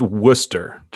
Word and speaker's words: Worcester: So it is pronounced Worcester, just so you know.